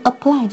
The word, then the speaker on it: apply: 这个地方要提醒大家，就是这个词的发音，因为 apply 这个词的重音在后，而 applicable 它的这个形容词，它的美式英语发音重音却在前。